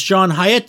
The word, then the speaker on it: Hyatt: Joining me now is John Hyatt.